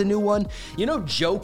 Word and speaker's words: Djo: He puts music out under the name Joe. That's spelled D-J-O.